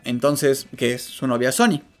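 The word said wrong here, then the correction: Sanni: Entonces, Sani, perdón, lo he dicho mal todo ese tiempo, qué menso yo.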